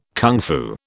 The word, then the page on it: kung fu: British English